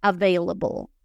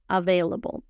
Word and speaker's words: available: Lo más importante es que pronuncien la V a V, a V, y que no pronuncien las As como As, sino como E, available, available.